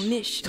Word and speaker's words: Misch: Misch. – Misch. – Misch. It's no T, so Misch. It wouldn't be Misch. – No. – Well, he's British. – It's M-I-S-C-H. – Tom Misch.